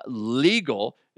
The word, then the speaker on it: L'Eagle: On May 9th of this year, starting at 11 o'clock in the morning, I'm going to be at Legal. It's spelled L'Eagle.